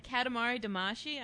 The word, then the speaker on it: Katamari Damacy: It's a Katamari Damacy.